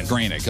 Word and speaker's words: Groening: So The Simpsons and the creator, Matt Groening, is that how you say it, or Groening? – Groening, okay.